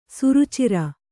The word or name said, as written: ಸುರುಚಿರ